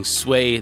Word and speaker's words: Sui: I'm using Sway. That's how it's pronounced because that's what it looks like in pinyin, Chinese pinyin. And so if I was going to say that in Chinese, it would be Sway.